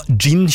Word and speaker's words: jinn: Gin tonic, ja. Nei, ja, det skrives med jod. J-I-N-N. Gin. Men også det uttales gin, for på engelsk skrives det med D først. Gin.